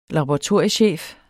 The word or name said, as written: laboratoriechef